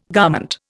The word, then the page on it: garment: Indian